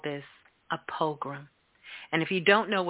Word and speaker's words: pogrom: He called it a program. A program. Is that how you say it? No. He called it. – It is a strange word. It's spelled P-O-G-R-O-M. – Like a pogrom.